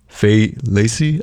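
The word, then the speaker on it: Fai Laci: Faye Lacey, I think is how you pronounce it. – Faye Lacey? – F-A-I space L-A-C-I.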